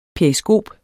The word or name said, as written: periskop